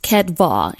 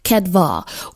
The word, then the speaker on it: chedvah: It's pronounced kedvah, and it's spelled C-H-E-D-V-A-H, kedvah, which means rejoicing or gladness.